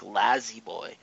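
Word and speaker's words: La-Z-Boy: There was, like, a space or a dash between, but it was L-A-Y, Lay, then the Z, then the B-O-Y. Now there is no Y, so it's L-A-Z-B-O-Y. It's like Lazy Boy. And it's pronounced that way now, too. So we're not just, you know, not taking them the way we're supposed to. Like, they pronounce it Lazy Boy now.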